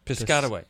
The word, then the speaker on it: Piscataway: From Piscataway, New Jersey. How do you say that? You know, I just Piscataway. – From how? Where? – Piscataway. – Okay, there's right there.